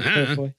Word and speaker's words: Claire Foy: Yeah, Claire Foy.